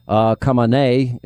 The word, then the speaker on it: Khamenei: Well, I don't know if you noticed this weekend, the Ayatollah Khamenei is the proper Israeli pronunciation, according to Bibi Netanyahu.